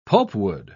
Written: pulpwood

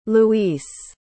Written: Luis

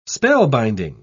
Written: spellbinding

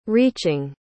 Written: reaching

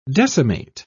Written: decimate

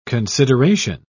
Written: consideration

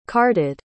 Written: carded

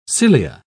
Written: cilia

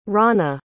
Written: ronna

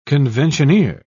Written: conventioneer

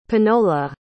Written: panola

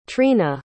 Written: trena

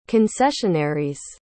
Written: concessionaries

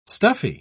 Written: stuffy

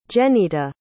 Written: jenitta

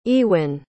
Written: Ewyn

